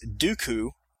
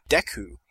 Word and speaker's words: Deku: Mr. Mals writes, my friends all pronounce the word D-E-K-U from Zelda Ocarina of Time as Dooku. – Wrong. – While I say Deku.